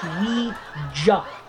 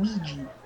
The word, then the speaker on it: Ouija: It's pronounced Ouija, not fucking Ouija.